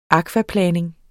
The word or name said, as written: akvaplaning